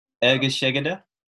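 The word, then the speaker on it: egészségedre: Can you pronounce it? – Was it Ergeshegede? – Once again? – Ergeshegede? – Yeah, very good.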